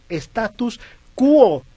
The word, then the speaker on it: Status Quo: Por acá Javier Leopoldo te corrige, Huicho, dice, el nombre del grupo se pronuncia Status Quo, con O al final.